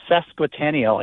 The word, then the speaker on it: sesquicentennial: And, you know, next year is Delano's sesquicentennial. I can't believe I pronounced that right.